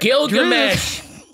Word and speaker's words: Gilgamesh: Ajax offers to wipe Thena's memory, but you know, Glisham volunteers to look over her. – Gilgamesh. Glisham. Gilgamesh. – Gilgamesh volunteers. Honestly, it's spelt the way it's pronounced.